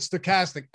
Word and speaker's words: Stokastic: Promo code Stochastic, STOKASTIC.